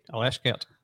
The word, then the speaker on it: Alashkert: Alashkert. Did I say that right? – Alashkert. – Alashkert at Ibrox on Thursday night.